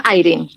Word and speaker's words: Eirin: Sí, yo digo Eirin, pero eh, cualquier cosa esté bien.